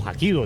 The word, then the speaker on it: Hapkido: lo dije bien. La liga. – Parkido. – Está, no, Hapkido, dije Hapkido. Está bien que yo soy mula, pero sí sé que es Hapkido.